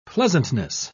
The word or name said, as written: pleasantness